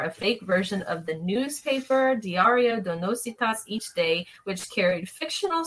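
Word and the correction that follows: Notícias: Noticias. Noticias. Sorry, I said that wrong.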